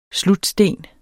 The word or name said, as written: slutsten